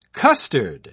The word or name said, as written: custard